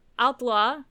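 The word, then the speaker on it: Haute-Loire: When establishing a headquarters for the Maquis in a barn in Haute? – I don't think they pronounce the H. I think it's Outlaw.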